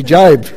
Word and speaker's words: Job: The book of Job, you can pronounce it Job, but it's actually Job, says, it's a whole book of the Bible, it's a long book in the Old Testament.